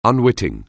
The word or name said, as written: unwitting